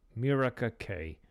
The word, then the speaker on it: Mirika: Okay, it's spelled M-I-R-I-K-A, Mirika Kay.